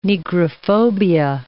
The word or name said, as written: negrophobia